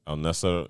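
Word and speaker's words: Al-Nassr: At the how do you pronounce it? Nasir? – Al-Nasser Football Club.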